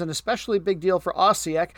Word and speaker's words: Osijek: O-S-I-J-E-K. Osijek.